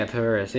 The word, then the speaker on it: PVRIS: Like Paris is pretty good. – I feel like I've heard of Paris. – It's like, it's spelled P-V-R-I-S. – Yeah, Paris, yeah.